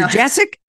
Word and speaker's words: Jasek: Dr. Jasek? Yep, yeah. I know her. – my name, yeah, it's like, it doesn't really look, it's really pronounced very much the way it's spelled, but it's just different.